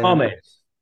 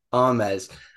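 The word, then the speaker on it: Armas: I love obviously Chris Evans and and um I can never pronounce her name Anna. Dem, how do you sell her? Say Armes. – Armes.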